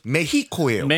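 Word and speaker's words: México: 멕시코는 영어식 발음이고 그 현지 발음은 메히코예요. – 메히코. 오늘 그럼 메히코로 가나요? – 메히코. – 네. – 왜냐하면 그 스페니쉬에서 X는 히읗 H로 발음합니다.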